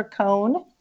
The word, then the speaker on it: Coan: They can find me probably the easiest place is on Instagram, Sandra Coan It's my Instagram name, and Coan is spelled C O A N.